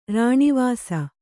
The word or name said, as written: ರಾಣೀವಾಸ